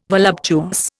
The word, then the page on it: voluptuous: Indian